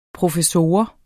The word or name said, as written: professor